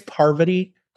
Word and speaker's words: Parvati: I believe it's Pavardi, not poverty, like I was saying, Pavardi. She's incredible. Is it Parvati? – It's Parvati.